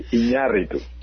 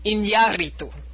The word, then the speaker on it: Iñárritu: Yeah, actually, very good, but the accent is on the A, Iñárritu. – Iñárritu.